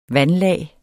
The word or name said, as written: vandlag